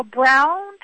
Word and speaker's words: Broun: This week, Georgia Congressman Paul Brown, and that's spelled B-R-O-U-N, he